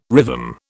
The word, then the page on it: rhythm: British English